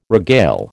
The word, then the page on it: regale: American English